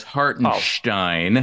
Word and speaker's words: Hartenstein: The names he had on this list were Kobe White, Denny Avdija, and Isaiah Hartenstein. So that leads to this question. Have you heard of any of these people? – Well, first of all, it's Avdija. Second of all, it's Hartenstein. Oh.